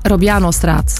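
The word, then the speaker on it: Robianostraat: 20 minuten verlies je ook op de A12 tussen E19 en de Robiano-straat. Zeg ik dat goed? – Ja, Robiano-straat.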